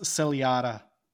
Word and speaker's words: ciliata: I'm not sure how to pronounce it. It's pronounced ciliata.